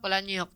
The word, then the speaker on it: Palahniuk: Chuck Palahniuk. – Oh, okay. Yeah, that's right. Yeah, is that how you say his name? – Yeah.